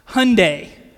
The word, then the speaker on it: Hyundai: Okay, so it is actually pronounced Hyundai. All right? And kind of like Sunday.